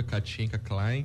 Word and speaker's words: Katienka Klain: A gerente de desenvolvimento de atividades artesanais da Fundação de Cultura, Katinka Klein, que eu espero ter pronunciado corretamente o sobrenome, é quem conversa com a gente hoje. Muito bom dia, Katinka. – Muito bom dia. – Tá certa a pronúncia aí, Katinka? Tá, tá certinho, assim mesmo.